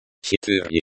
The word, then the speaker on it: Четыре: Четыре. Pronounced like четыре, with the E at the end like the in mother.